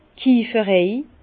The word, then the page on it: d' Kieferei: Bas Rhin; Herrlisheim